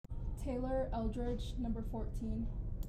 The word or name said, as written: Taylor Eldredge